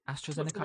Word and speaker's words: AstraZeneca: AstraZeneca, I believe, recently.